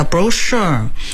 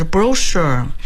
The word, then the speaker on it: brochure: brochure 本来就是个法语词过来的，一般重音在后面的词带有很重的法语痕迹，因为法语的发音在后面叫 brochure，可是呢变成英语以后呢就是 brochure，所以你说 brochure 和 brochure 都可以。